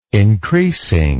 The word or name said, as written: increasing